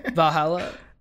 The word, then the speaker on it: Valhalla: Valhalla. – I think that's right there. Valhalla. – That is the name. Yeah. – Isn't it heaven? – I mean, Valhalla is like it's like where Greek gods.